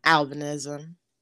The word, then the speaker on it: albinism: And a lot of people, because they haven't heard the word albinism, but they've heard the word albino, they'll try to like mash them together.